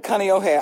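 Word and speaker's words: kaneohe (Kāneʻohe): When you said kaneohe, I was absolutely just...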